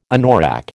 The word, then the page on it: anorak: American English